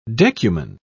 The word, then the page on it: decuman: British English